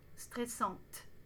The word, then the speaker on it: stressante: Pensez à bien prononcer le T au féminin singulier et pluriel. Stressante.